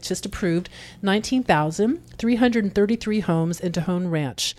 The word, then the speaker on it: Tejon: Tejon, T-E-J-O-N Ranch. Tejon Ranch.